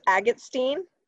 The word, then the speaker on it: Agatstein: Well, it was Agatstein, A-G-A-T-S-T-E-I-N. It's my parents are from the from Ukraine originally. – Really? – Jewish Ukrainian. Yeah. So it's people can never really pronounce it, and so I was like pretty excited to take a more simple name.